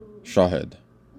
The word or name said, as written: shahed